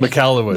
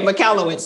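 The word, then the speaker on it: Michalowicz: And so Mike, I'm going to butcher his last name, Mikalski, I think. – Mikalowicz. – But if anyone, okay, Mikalowicz, see, I had it wrong.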